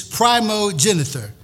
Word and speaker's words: primogeniture: Yeah, it's primogeniture.